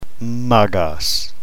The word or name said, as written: magos